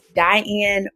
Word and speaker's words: Diane: My name on all social media platforms is Diane Boyd, D-I-A-N-E, last name B-O-Y-D.